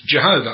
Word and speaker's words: Jehovah: Now, why have I said Yehovah? That is the Jewish pronunciation. We're not Jews, we're English. And the most commonly accepted form is Jehovah.